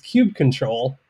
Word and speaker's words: kubectl: Interestingly enough, in an older release of Kubernetes, in the release notes, it actually shows you how to pronounce it as a canonical rule, and it's kubectl.